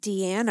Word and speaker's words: Diane: Deanne Arbus. – Deanne. – Deanne. – Why is it written as Diane? – Well, it's spelled as Diane, but she pronounced it Deanne. – So let's say Diane then.